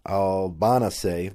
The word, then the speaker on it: Albanese: And this Italian lawyer, Francesca Albanese, that's how it's pronounced, who was appointed the UN Special Rapporteur on the situation of human rights in the Palestinian territories.